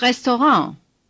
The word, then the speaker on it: Restaurant: Das Restaurant. – This word is taken from the French and does not follow the rules of German pronunciation. The stress is on the last syllable, pronounced en.